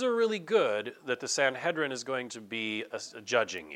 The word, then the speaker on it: Sanhedrin: In the ancient land here of Israel, there's a court system called the Sanhedrin. Some people pronounce that Sanhedrin. I won't pick on you if you do.